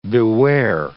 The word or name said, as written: beware